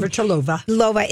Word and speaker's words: Navratilova: Navratilova is back commentating.